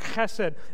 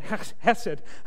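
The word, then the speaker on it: chesed (חסד): That word in the original language is the word chesed. If you say it like that, but don't spit on anybody, right, because of virus. But chesed. Chesed is the word.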